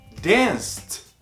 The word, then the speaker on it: danced: Então, dance e depois você pronuncia o T. O T. Danced. Esse E não vai ser pronunciado, porque ele não é pronunciado nem no presente, não vai ser agora no passado que a gente vai colocar ele lá. Danced. Watch, que é assistir.